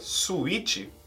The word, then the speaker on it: suite: Caso você leia suite, primeiramente vamos para a pronúncia. A pronúncia é suite. Suite. Igual a pronúncia de doce. A suite não é a suíte do Brasil.